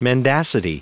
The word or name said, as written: mendacity